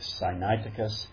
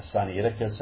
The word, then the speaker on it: Sinaiticus: Some people pronounce it as he does, Codex Sinaiticus. I was thinking about that. I've always pronounced it Codex Sinaiticus,